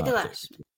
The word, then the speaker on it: Delashmit: Hayley Delashmit.